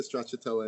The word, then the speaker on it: stracciatella: I had the mushroom and stracciatella. Is that how you pronounce it? – Yeah, delicious. – We make the stracciatella in house, and then it's got a little bit of green onion on it as well.